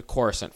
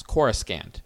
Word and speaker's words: Coruscant: Now there's an interesting historical footnote here, which is Thrawn pronounces the planet's name as Coruscant, because this game is released well before the Phantom Menace established the official pronunciation of the planet as Coruscant.